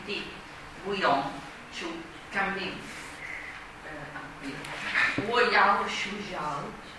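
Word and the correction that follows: Shui jiao (睡觉): Allora, questo è sbagliato. Shui jiao. Ricordate che era un i si pronuncia wei, quindi quel sh un i si pronuncia shui.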